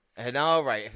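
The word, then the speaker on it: Henare: And Aaron Hinare, is that the right way to pronounce it? Defeated... – Hinare.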